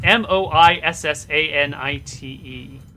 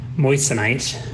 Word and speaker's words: moissanite: How the hell do you pronounce this? Um, M O I S S A N I T E. – Moissanite.